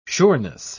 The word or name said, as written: sureness